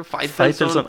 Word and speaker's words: Faitelson: Faitelson. Así se pronuncia, Faitelson.